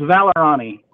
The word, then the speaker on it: Vallorani: Uh, Brandon, am I pronouncing it correctly? It sounds like an Italian name. It should be pronounced correctly. it's it's Vallorani.